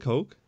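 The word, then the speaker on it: Koch: Is it is it Coke? I know it's K O C H, but that's what it's pronounced. Coke.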